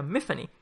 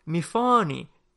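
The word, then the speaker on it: Myfanwy: Sin embargo, sin embargo, el mismo autor en esta misma primera página nos dice eh, este nombre, mi nombre lo pronuncio de forma que rima con Tiffany, con lo cual se pronuncia Mifani.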